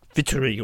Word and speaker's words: Waltari: And yes, I still know it's pronounced Vittery or something like that, but it wasn't until one of the Squirrel's Finnish friends pulled her up on it in conversation that we realised we'd been saying it wrong for years.